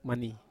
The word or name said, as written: mani